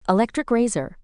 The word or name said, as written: electric razor